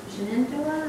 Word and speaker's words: Shenandoah: There's an editor that does the Shenandoah review, and which really is Shenandoah. That means deer in Mohawk. And they just mispronounce it.